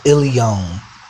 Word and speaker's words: Aeileon: But from my last name, I had a stage name, a rap name, by the name of Ilion, and it's spelled A-E-I-L-E-O-N. And you could Google that, A-E-I-L-E-O-N, and you'll find a wealth of music.